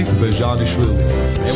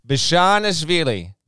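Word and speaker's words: Bezhanishvili: Here's how you say his name for everyone listening. – It's pretty easy. It's Georgie Bajanishvili. – Here we go. Bajanishvili. Bajanishvili.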